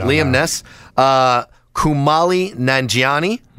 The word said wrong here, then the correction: Kumail: Or Kumail, excuse me. I mispronounced his name.